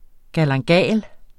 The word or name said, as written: galanga